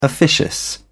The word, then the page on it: officious: British English